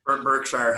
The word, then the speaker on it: Berkshire: he he's been bashing gold his whole life, and now he's buying gold with uh, Buckshire. Uh, what is it? Um, uh, Buckshire Hathaway. I'm, I'm saying it wrong. – Berkshire Hathaway. Berkshire Hathaway.